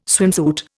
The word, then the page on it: swimsuit: Indian